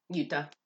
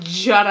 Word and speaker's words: Jutta: It's very German. The J is silent. J is pronounced. – Yeah, I figured it wasn't Jutta. I was like, there's no way this name is Jutta.